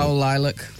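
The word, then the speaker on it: coeliac: Now, celiac looks like it's spelt wrong. – Yeah, so celiac is something more... – Coliac. – That's it.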